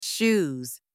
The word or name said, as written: shoes